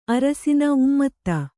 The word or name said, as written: ಉಮ್ಮದ